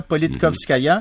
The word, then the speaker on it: Politkovskaya: Politkovskaya, merci, là, Politkovskaya. Mm-hmm. Bon, je tiens à le prononcer comme du monde, son nom, là, parce qu'elle mérite, honnêtement, là, elle est passée à l'histoire comme une journaliste absolument...